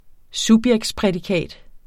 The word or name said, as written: subjektsprædikat